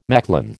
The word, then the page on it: Mechlin: American English